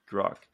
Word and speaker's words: Grok: You think Grok is correct? – Grok is correct, yes. – Grok.